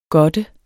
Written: godte

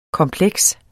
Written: kompleks

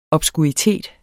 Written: obskuritet

